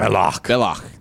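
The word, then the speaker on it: Belloq: Belloc.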